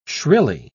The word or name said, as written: shrilly